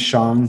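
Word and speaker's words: Xiong: You know, my first name is Kong, last name is Shong, first name is spelled K-H-O-N-G, last name is X as in X-ray, I-O-N-G.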